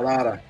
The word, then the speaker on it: Madara: También de Naruto, Madra Uchiha. – Oh, Madara, Madara, Madara Uchiha.